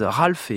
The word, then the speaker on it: Ralph: Je dis bien Ralph puisque à plusieurs reprises j'avais prononcé ce prénom à la française, Ralph, et bien des auditeurs m'ont signalé que la exacte prononciation, effectivement, c'est cela. C'est bien Ralph Vaughan Williams,